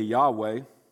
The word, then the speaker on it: Yahweh: We pronounce it today Yahweh.